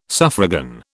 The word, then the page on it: suffragan: British English